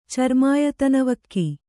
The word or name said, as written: ಚರ್ಮಾಯತನವಕ್ಕಿ